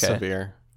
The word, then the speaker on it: Sevier: I don't know if I'm saying that correctly. – It's severe.